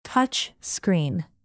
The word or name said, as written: touch-screen